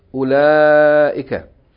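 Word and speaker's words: أولئك: It's not pronounced ula. It's pronounced ulaika. Note that the wow in ulaika is not pronounced.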